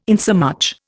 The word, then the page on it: insomuch: Indian